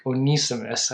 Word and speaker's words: Onesimus: That's how you pronounce it in the Greek, Onesimus.